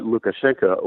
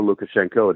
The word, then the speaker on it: Lukashenko: I think that's a threat that Lukashenko or Lukashenko, depending on whether you pronounce his name with Russian phonetic spelling or Belarusian, the leader since 1994, has been trying to forestall that possibility of Russian aggressive intervention by cracking down on the protests themselves.